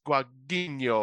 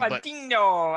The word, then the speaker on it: Guadagnino: And this was directed by Lucas Guadagnino, or how I probably butchered his name. – Guadagnino, I think that sounds good.